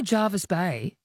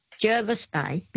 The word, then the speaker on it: Jervis Bay: Because that's the way the locals say it? Oh, that's the way it's spelt? – Yes. – Oh, you say it the way it's spelt, not because the locals say. – See, they say Jervis Bay.